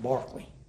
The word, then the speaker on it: Berkeley: It looks like Berkeley. – Oh. Okay. – Like the city in California, or the college in California. It's pronounced Barkley.